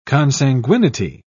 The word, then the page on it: consanguinity: British English